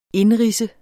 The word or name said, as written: indridse